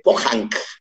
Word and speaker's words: Pohang: Está dentro, de, dentro del, del mar, en la playa Omigot de, de Pohang. Así es que así se pronuncia.